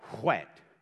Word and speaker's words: whet: It's W-H. It's actually whet. I overpronounce that, I know.